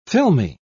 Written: filmy

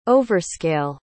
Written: overscale

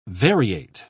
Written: variate

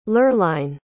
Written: lurline

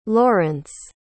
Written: lorence

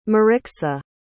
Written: Marixa

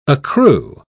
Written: accrue

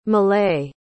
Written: malay

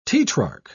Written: tetrarch

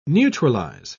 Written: neutralize

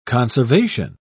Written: conservation

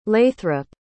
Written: lathrop

